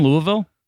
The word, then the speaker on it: Louisville: Were you in Louisville? – Yeah. – Thank you for saying it correctly.